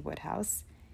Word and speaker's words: Wodehouse: So his name is written Wodehouse, but it's pronounced Woodhouse.